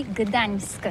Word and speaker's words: Gdańsk: Oui, c'est pas très facile pour des étrangers, mais remarque bien que la langue polonaise, c'est la langue où on prononce chaque lettre qu'on écrit. C'est pourquoi on dit Gdańsk.